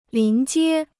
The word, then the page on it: 临街: lín jiē